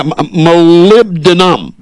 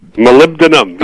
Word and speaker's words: molybdenum: let's try and pronounce this. I couldn't do it last time. Molybdenum. Have I got it? – Yep, moly for short. – Yeah, molybdenum. – Molybdenum, yes.